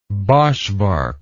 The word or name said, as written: boschvark